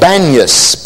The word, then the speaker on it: Banias: The city today is called Banias because the Arabic-speaking people can't pronounce a P, so they call it Banias instead of Panias.